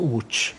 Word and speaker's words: Łódź: pisze się loc, a wymawia się łódź.